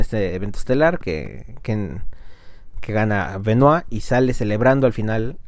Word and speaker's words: Benoit: Bueno, este tema en particular es sobre el ex luchador profesional y salón de la fama del deporte en Canadá, Chris Benoit. Pronuncio así su apellido porque es francés.